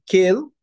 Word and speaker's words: Kel: que é Kel, se pronuncia com E, Melech Neeman.